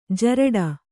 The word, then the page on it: ಜರಟಿ: jaraṭi; Kannada